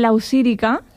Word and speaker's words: Lausírica: Lo has dicho bien, con el acento un poco así cambiado sería Lausírica, pero okay. Siempre me dicen Laurisica, pero ya estoy acostumbrada, pero vale.